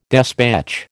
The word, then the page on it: despatch: American English